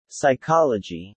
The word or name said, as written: psychology